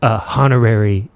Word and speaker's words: honorary: Will Smith is like a honorary He-Manologian. – Honor? Honorary? You know the H is silent, right?